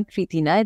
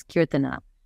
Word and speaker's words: Kirtana: And I send it back to them and say, my name is not Kritina, it's Kirtana. Very mm-hmm. clearly, there's a A after a T.